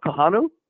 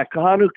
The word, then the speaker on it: Kahanu: Kanua Kai, linebacker, is in the current class. Would I have that right? Vince and Robbie, anybody know about? I'm not sure I'm pronouncing. – I'm sorry? – I'm not sure if I'm pronouncing his name right. K-A-H-A-N-U-K-I-A. A linebacker. – Are you talking about Kahanu? – Yes. There you go.